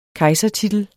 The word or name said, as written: kejsertitel